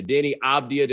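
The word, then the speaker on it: Deni Avdija: and he's trying to pass the ball to Denny Abdia. Did I say his name right, Clinton? You actually watched these guys play basketball. – Yes, you did, and unfortunately, right, I do watch these guys play.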